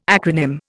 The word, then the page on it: acronym: Indian